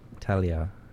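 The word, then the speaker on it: Thalía: Talia. Yeah, there's no H pronunciation in that.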